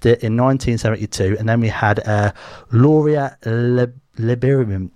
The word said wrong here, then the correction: Lieberman: I can't even pronounce that. Do you know how to pronounce that? Hang on, I'm going to ask someone off air here. Do you know how to pronounce that? – Lieberman. – There you go. Loria Lieberman, yeah? There you go. I said it right that time.